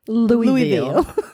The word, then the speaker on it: Louisville: I just said that wrong. Louisville. Louisville. – It does look like Louisville. – That's what it says right here on my paper. Right. But I know it's Louisville.